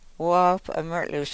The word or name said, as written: woop o mrlus